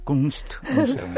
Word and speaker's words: Kunstmuseum: Son un préstamo de 176 obras del Kunstmuseum. No sé si lo he pronunciado bien, el museo suizo. – Bueno, en Suiza depende. – No te va a desmentir nadie. – Nadie. – Vale, Klingling, pues el Kunstmuseum. – Kunstmuseum. – Perfecto.